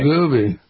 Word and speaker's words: Wegovy: Am I pronouncing it right? Wegovy. – Yes, right. Wegovy.